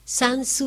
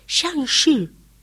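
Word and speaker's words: Shanxi: Sansi buscaba. Y no, ¿sabes cómo se dice? Shangshi. Y él me hablaba en dialecto, Sansi. Shangshi.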